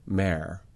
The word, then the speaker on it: mayor: If I think about the word mayor, which is two syllables, may, face, that's often said as mare. – Right. Similar and to a homophone for the lady horse.